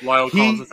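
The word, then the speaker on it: La'el Collins: That was, is it Lyle Collins or however you pronounce it? – Lyle Collins.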